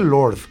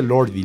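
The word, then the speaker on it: Lorde: Se dice Lord. Esa ahí al final va extra. O sea, es por cuestiones estéticas de la escritura. No se dice Lordi.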